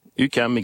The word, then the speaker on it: UQAM: Mais vous êtes, comment ça se prononce ? UQAM, comment dites-vous ? – UQAM, exactement.